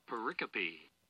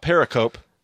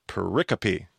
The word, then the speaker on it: pericope: Pericope. – Pericope. So not pericope, apparently, but pericope. All right. So every little pericope in the book of Job has some form of parallelism.